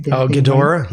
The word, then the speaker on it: Ghidorah: I think they had a real problem with Godzilla eating Ghidorah, am I pronouncing it? – Ghidorah, name? Yeah.